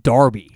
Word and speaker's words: Derby: Is in England, they always pronounce Derby Darby.